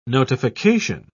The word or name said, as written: notification